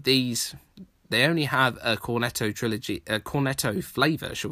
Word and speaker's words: Cornetto: And the same applies to my next recommendation the Three Flavors Cornet Cornetto trilogy, say that properly,